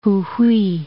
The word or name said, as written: Jujuy